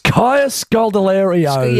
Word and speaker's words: Kaya Scodelario: It's Kaya Scodelario.